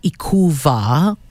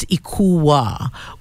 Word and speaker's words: Ikuwa: So it's not Ikuva. It's not Ikuva. That is incorrect. It's Ikuwa with the soft pronunciation of the W.